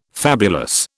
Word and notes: fabulous: British English

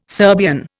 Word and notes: Serbian: Indian

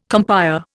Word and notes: compère: Indian

